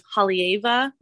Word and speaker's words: Haleiwa: Like we have Haleiwa, which is, I butchered that pronunciation too, but the W in there is a V.